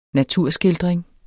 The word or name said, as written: naturskildring